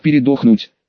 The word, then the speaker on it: передохнуть: то принцы могут передохнуть, именно с ударением на О.